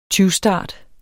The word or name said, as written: tyvstart